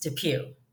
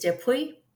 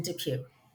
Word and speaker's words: DePuy: My last name in the United States, where we live, where I was born and raised and still reside, is pronounced DePuy. The French pronunciation, I'm told, is DePuy. So if I lived in a French-speaking country, I'm sure that's the pronunciation I would use. But here in the good old US of A, it is still and has always been DePuy.